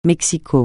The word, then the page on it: Mexico: francais